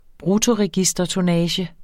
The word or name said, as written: bruttoregistertonnage